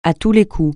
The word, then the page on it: à tous les coups: francais